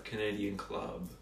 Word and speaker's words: Canadian Club: So you guys pronounce Canadian clubs like Canadian club, not Canadian club. – Canadian club. Emphasis on the club, not the Canadian.